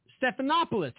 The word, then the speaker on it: Stephanopoulos: Yeah, Stephanopoulos. Stephanopoulos. Hey. – Yeah, there you go.